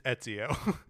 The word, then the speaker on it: Ezio: There's no N in that word. It's just Ezio.